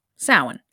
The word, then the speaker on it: Samhain: Samhain, pronounced Samhain, spelled S-A-M-H-A-I-N, is